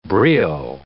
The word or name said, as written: brio